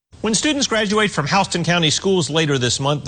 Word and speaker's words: Houston: It's spelled like Houston, but it's pronounced Houston.